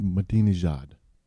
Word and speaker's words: Ahmadinejad: Ahmed Ahmadinejad.